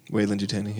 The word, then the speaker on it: Weyland-Yutani: Weyland-Yutani, yeah.